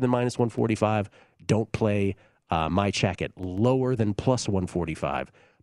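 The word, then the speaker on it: Majchrzak: But I'm going with Kamil, I'm going to try to say this right, Majak. Now, it's not spelled that way. It's spelled like it's Majchurzak. It's pronounced Majak. But Kamil Majak is about, I got it at plus 168 last night against Filip Krajinovic.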